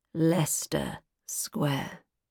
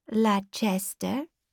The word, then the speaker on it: Leicester: Leicester Square, two syllables. – Why not Leicester? – No idea.